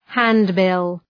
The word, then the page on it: handbill: ‘hænd,bıl